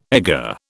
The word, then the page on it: egger: British English